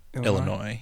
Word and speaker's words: Illinois: Illinois. Yeah. – Is that how that's pronounced? – Yeah.